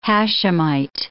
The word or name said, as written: Hashimite